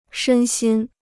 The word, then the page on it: 身心: shēn xīn